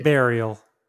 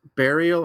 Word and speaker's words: Burial: So how do you pronounce it? Burial or Burial? Because it's spelled B-U-R-I-A-L. So you would think it'd be Burial, right? – Well, I would say Bury because... – I mean, when I also say Bury. – When you're going to bury something, it's still spelled B-U-R-Y, Bury, but it's pronounced Bury.